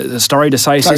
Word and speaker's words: stare decisis: And we have this appellate doctrine called stare decisis. Is that how you pronounce it? Yeah.